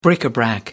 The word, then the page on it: bric-a-brac: American English